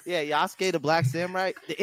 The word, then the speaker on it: Yasuke: Oh yeah, I like Sasuke. The Sasuke or Yasuke? – It's Yasuke, sorry.